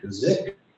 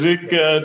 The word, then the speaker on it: Czikk: Zick, I believe how you pronounce the last name, C-Z-I-K-K, or $515.11. – Yeah, Mr. Zick did come in and did a registration